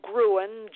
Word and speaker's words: Gruen: And my last name is spelled Gruen, G-R-U-E-N.